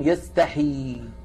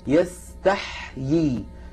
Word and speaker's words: يستحيي: فكثيرا ما نسمع من بعض الإخوة المبتدئين أنهم يقرؤونها بياء واحدة ويكسرون الحاء، فيقولون يستحي يستحي، هذا لا يصح، لا بد من نطقها هكذا يستحيي. لاحظوا تح، الحاء تبقى على سكونها. إن الله لا يستحيي أن يضرب مثلا، هكذا تنطق هذه الكلمة.